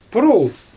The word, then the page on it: purul: Xyzyl